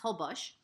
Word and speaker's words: Holbox: which is spelled Holbox but pronounced Holbox, including incredible adventures.